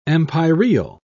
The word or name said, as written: empyreal